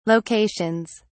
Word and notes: locations: British English

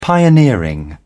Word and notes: pioneering: British English

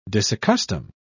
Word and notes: disaccustom: British English

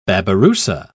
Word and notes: babirusa: British English

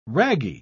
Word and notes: ragee: British English

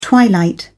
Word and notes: twilight: British English

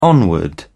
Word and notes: onward: British English

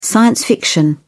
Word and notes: science fiction: British English

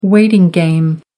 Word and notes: waiting game: American English

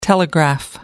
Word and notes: telegraph: American English

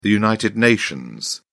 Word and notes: united nations: British English

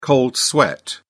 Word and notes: cold sweat: British English

